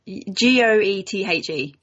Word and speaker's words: Goethe: G O E T H E. Yeah, that's pronounced Goethe.